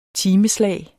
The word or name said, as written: timeslag